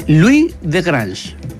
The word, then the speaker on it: Louis de Grange: Louis de Grange. – Es Louis de Grange. – O sea, se escribe igual, Louis. – Louis. – Como la mamá de Malcolm.